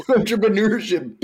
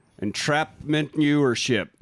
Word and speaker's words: entrepreneurship: Yeah, but the entrepreneurship baby cakes. – Yes, but the way he delivers the line sounds like entrapment newership. Like he mispronounces it and they just kept it in.